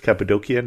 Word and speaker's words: Cappadocia: I have a little trouble when I'm going to pronounce Cappadocia, because we tend to pronounce it that way in the church. If you're in modern Turkey, it's Cappadocia now, but it's still a region that you can go to with its magnificent spires and troglodytes and things like that.